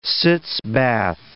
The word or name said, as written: sitz bath